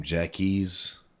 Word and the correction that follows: Jacquees: Featuring Dej Loaf. – Oh, okay. Jacquees.